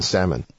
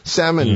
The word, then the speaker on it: salmon: 没错，它有一个很出名的事情呢、啊，这个都已经拍了纪录片下来、嗯、应该是华盛顿州这边有一个养殖这个 salmon、啊、那个字应该是念 salmon、嗯、那个 L 其实不发音的 salmon 的这个养殖鱼苗的地方，它是当然就是在一个人住的地方啊，有池塘啊，这个池塘你不能够随便让水流出去，那个鱼苗很小它就跑掉了。